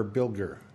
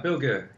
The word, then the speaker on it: Bilger: Is that how you pronounce it, Bilger or Bilger? – Bilger, yeah.